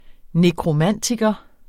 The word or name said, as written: nekromantiker